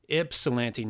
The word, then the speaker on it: Ypsilanti: Ypsilanti, not Ypsilanti. It's Ips. – Correct. It is Ip.